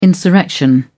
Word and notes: insurrection: British English

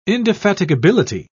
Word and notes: indefatigability: British English

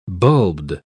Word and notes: bulbed: British English

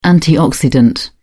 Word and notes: antioxidant: British English

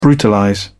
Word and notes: brutalize: British English

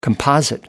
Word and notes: composite: American English

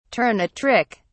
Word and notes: turn a trick: British English

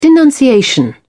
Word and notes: denunciation: British English

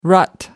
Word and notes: rut: American English

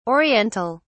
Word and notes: Oriental: British English

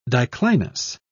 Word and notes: diclinous: British English

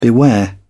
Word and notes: beware: British English

